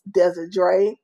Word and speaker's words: Desiree: It's hashtag the talk with W-I-T, Dez, D-E-S show. Or you can DM me on Facebook, Desiree, D-E-S-I-D-R-E. So it's like pronounced Desiree. Then it's Monique, M-O-N-I-Q-U-E.